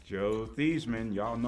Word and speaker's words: Theismann: Nathan, he went to Notre Dame, had a chance to win the Heisman Trophy, so he changed his name from Theismann to Theismann to rhyme with Heisman.